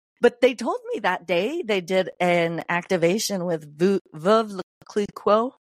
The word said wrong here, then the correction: Veuve Clicquot: I always say it wrong, but it's that really high-end champagne. – Yeah, okay. – Veuve Clicquot. – Yes, I know who you're talking about. – The Clico, yeah.